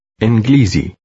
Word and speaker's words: إنجليزي: Inglizy.